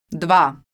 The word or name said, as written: dva